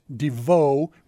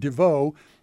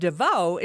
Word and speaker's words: Devo: We've talked recently about such groups as Devo, which is how they pronounce it. Not us. Everybody else has ever pronounced it Devo, but they pronounce it Devo, which is streaming on Netflix, directed by Chris Smith, the guy who did Wham, American Movie, and Tiger King.